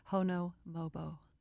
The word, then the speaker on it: Honomobo: Hono Mobo. – Hono. – H-O-N-O. M-O-B-O. – H-O-N-O-M-O-B-O. It's kind of like Mississippi. M-I-S-S-I.